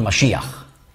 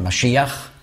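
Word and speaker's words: Mashiach (משיח): Oder Mashiach. Das ist die hebräische Aussprache Mashiach, die griechische Umschrift dieses Wortes Messias. Aber nicht Übersetzung, sondern Umschrift. Die Griechen konnten nicht Sch sagen.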